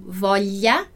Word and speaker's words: voglia: Então, para fazer esse som do GL, eu necessariamente preciso ter um I depois do L, ok? Tanto é que na palavra voglia, a gente pronuncia esse I, né? Ele tá ali implícito. Embora, no português, a gente reproduza esse som escrevendo LHA, ok? Então, voglia, voglia, eu sempre vou escrever GLIA. Não posso escrever GLA diretamente, porque senão seria vogla, né?